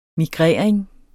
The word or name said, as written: migrering